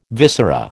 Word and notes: viscera: American English

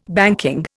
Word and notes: banking: Indian

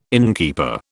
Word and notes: innkeeper: British English

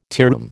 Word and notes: tearoom: American English

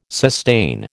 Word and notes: sustain: American English

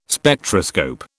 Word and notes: Spectroscope: British English

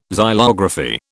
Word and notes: xylography: British English

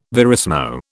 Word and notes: verismo: British English

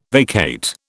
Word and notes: vacate: British English

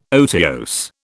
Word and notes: otiose: British English

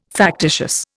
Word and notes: factitious: Indian

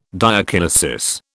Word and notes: diakinesis: British English

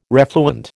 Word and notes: refluent: American English

